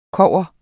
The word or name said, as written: kogger